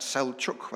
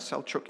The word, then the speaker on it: Selçuk: Firstly, Ephesus was an important port located near modern-day Selçuk, which I had to look up on the internet as to how to pronounce that. Selçuk, whether the C cedilla is a ch sound, Selçuk in Turkey.